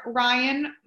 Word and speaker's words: Ryann: So contacting me, I am on Facebook under Ryann. My name is spelled R-Y-A-N-N. So there's 2 N's. And then Halo, H-A-L-O.